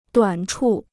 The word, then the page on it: 短处: duǎn chù